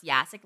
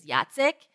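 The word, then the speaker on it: Jacek: It's actually not pronounced Yasik, like, the proper pronunciation. He's going to roll his eyes because I don't know. Apparently, I never do it right. But the proper Polish pronunciation is Yacek.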